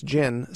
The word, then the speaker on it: Djinn: Now that's gin, spelled D-J-I-N-N. Hearthcock says they got the name from a game of Scrabble. – The D is silent, so it's just pronounced gin, and it's a spirit.